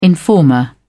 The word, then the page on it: informer: British English